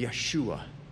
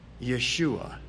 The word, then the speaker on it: Yeshua: In the, if you were to pronounce Jesus, or excuse me, uh, if you were to pronounce Joshua's name in Hebrew, it would be Yeshua. If you were to pronounce Jesus' name in Hebrew, it would be Yeshua.